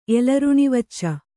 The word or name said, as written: ಎಲರುಣಿವಚ್ಚ